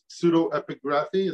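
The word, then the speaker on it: pseudepigraphy: Pseudo-epigraphy.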